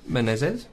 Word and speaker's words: Menezes: If only to pronounce Menezes. – That's correct. – So dig. – Menezes. – Dig. – That's how you say it.